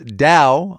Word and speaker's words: DAO: There's DAO, pronounced DAO, I'm told, a decentralized autonomous organization, kind of an online crypto kibbutz of sorts.